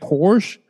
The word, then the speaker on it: Porsche: It's technically Porsche. I think you're trying to go fancy with it, huh? – I think the American pronunciation keeps pronouncing it Porsche. But the correct way is Porsche.